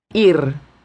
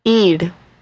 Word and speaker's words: ir: Listen to these three different pronunciations of the verb to go. To go. – Ir. – Ir. – Ir. – Did you hear that? Listen again. To go. – Ir. – Ir. – Ir. – Three different pronunciations of the Spanish R, all completely understandable by native Spanish speakers.